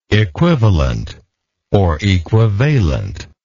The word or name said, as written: equivalently